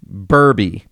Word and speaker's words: Burby: Jessica Furby. Burby. Jessica Burby rhymes with Furby.